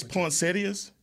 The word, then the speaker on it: poinsettias: I'm like, you said it's poinsettias? – That's what it is. – I've been saying it wrong for my whole life.